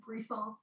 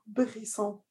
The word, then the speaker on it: Brisson: It's a French name. That's why I left. – Oh, is it? – Yeah, it's like Brisson. – Brisson.